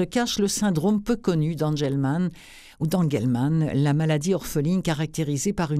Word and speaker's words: Angelman: Il a 3 ans, il est atteint du syndrome d'Angelman, on va dire d'Angelman. Ça s'écrit A-N-G-E-L-M-A-N.